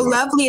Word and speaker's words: LoveLee: So, Lovely is spelled L-O-V-E-L-E-E. Again, L-O-V-E-L-E-E.